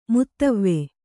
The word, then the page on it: ಮುತವ್ವೆ: kannada